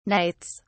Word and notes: knights: British English

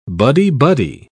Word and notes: buddy-buddy: British English

